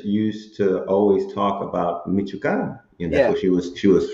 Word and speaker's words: Michoacano: But we are super excited today to have Miguel El Michoacano, right? That's how you say it, Michoacano. – Michoacano, yeah.